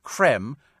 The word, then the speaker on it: creme: It doesn't have a thing, because just like Nestle with the acute accent, so it's still pronounced creme, as in ice cream.